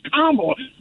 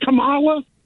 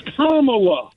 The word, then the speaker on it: Kamala: And it's Kamala, not Kamala. It's Kamala.